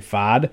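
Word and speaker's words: Pfaadt: They are starting rookie Brandon Fodd. I think it's Fodd. I think the T is silent. And I think the P is silent too. But either way, Brandon, with a P-F-A-A-D-T last name, is starting.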